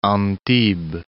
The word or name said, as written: Antibes